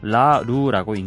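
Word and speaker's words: Laroux: 라루라고 있는데 스펠링은 L A R O U X라고 합니다.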